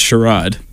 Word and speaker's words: Charades: It's pronounced Sherrod. – It's French.